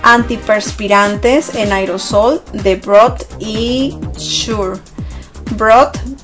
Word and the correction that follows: Brut: se escribe B-R-U-T, Brut se escribe, y Sure es S-U-R-E, Sure, ¿ok?